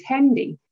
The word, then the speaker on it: Hendy: And then my last name is Hendy. I actually have a rhyming name.